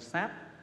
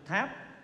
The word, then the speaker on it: sáp: Bất đắc giữ sư đồng thất, vậy đồng sáp. Chữ này có hai âm đọc, thường chúng ta đọc là chữ tháp, nhưng mà cụ xưa nay đọc là sáp, sáp.